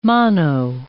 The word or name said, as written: mano